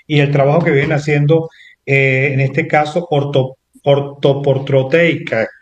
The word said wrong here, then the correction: ortoprotécnica: Creo que lo dije bien, ¿no, Bianca? – Ortoprotécnica.